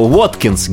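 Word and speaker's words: Watkins: А Уоткинс гитара была. Не слово водка, нет этого слова. – Понятно, Уоткинс. – С буквы W пишется, да, Уоткинс.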